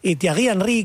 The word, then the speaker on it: Thierry Henry: Thierry Henry, que es el jugador francés.